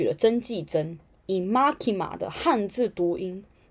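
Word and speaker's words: マキマ: 好，那我刚刚讲到 Makima，好，他的名字呢就是曾纪珍啊，哈，他的名字是片假名，好，片假名的发音是 Makima，没有汉字的。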